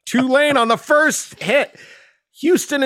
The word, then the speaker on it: Tulane: Did I say Tulane correct? – Yeah, they got.